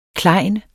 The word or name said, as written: klejn